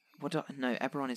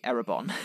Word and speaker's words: Eberron: I've always said it's Eberron, rising from the last war. – Yeah, see, I'm wrong. I always say... Eberron is right. I used to say Erebon.